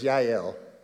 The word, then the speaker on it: Yael: The Hebrew pronunciation of it is Yael, a Gentile woman.